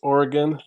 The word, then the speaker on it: Oregon: Yes, somebody said it is pronounced Oregon. This is true. I also got it wrong in the SD for All show, and half of the comments were about me saying it wrong.